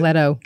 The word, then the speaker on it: Leto: Leto.